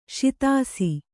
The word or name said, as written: ಸೋದಿಸು